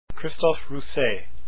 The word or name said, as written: rousset